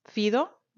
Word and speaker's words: Fido: O bueno, no sé, por lo menos a mí, pues yo estoy con una que se llama Fido, Fido, así si lo, si lo queremos describir como suena. Bueno, no, si lo queremos decir como se escribe, Fido.